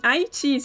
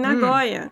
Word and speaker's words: Nagoya: Ele vai ser aberto em Haiti, se eu não me engano, eu não sei pronunciar direito, mas é em hum. – Nagoya.